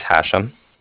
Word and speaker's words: Hashem: So my name is Hashem. That's H-A-S-H-E-M, as in mother at the end.